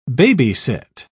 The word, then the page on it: baby-sit: British English